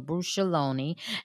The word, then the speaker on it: bruschettone: So be sure to look for that at the festival, the bruschettone, and that's being made. Am I saying it correctly, Christy? – Yes.